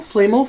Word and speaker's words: Flamel: Nicholas Flamel? – Flamel. – Flamel, there we go.